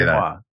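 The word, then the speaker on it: génoise: G-E-N-O-I-S-E. Genoise? Genoise? – Yeah. – How do you say Genoise. – That? – S-E? – S-E. Genoise. Yeah. – Yes. There's an E after the S. You pronounce the S. Otherwise, it's just Y.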